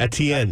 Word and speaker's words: Etienne: Etienne.